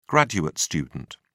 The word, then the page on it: graduate student: British English